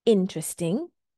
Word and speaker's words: interesting: And the stress here falls on the first syllable. So it sounds like this. Interesting. Interesting. And I've made my higher pitch there. I made my pace slightly slower when I say in. So it's not interesting. It's interesting.